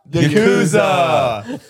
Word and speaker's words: Yakuza: The Yasuka? – Yeah. – Isn't it the Yasuka? Am I saying that right? – Something. It's Yakuza. Yasuka.